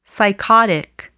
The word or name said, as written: psychotic